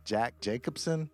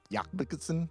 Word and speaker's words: Jakobsen: That's when the company was founded in Oslo, Norway, by a guy whose name looks like Jack Jacobson, but it's pronounced Jakobsen.